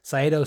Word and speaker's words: Saito: It was a middle rope, Saito suplex. Did I pronounce that correctly? – Yeah. – Okay, good.